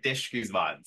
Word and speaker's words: Disch-Guzman: It's Ryan Disch-Guzman. You're good. You're very close.